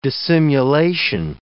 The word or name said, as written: dissimulation